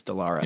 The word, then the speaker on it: Dallara: Dallara, I always get this pronunciation. Dallara? Dallara. – I don't know. – I think it's Dallara. – Yeah, I think so.